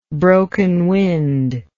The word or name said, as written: broken winded